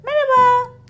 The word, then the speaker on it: merhaba: Merhaba, merhaba, merhaba. Looks like that. Merhaba. H is silent there. But when you write it down, H is there.